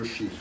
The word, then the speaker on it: Rishi: And when you see that Rishi, that S with a dot under it, it's pronounced like the German Sprechen, like Sprechenge Deutsch. So you see SH is, it's a very so like the SH sound there.